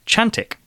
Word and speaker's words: Cantik: if we could call a bear Chantik. This is spelled C-A-N-T-I-K, but pronounced Ch.